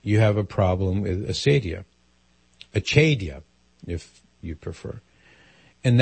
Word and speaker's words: acedia: It's called acedia, A-C-E-D-I-A. Well, some people pronounce it the more the Latin way, acedia.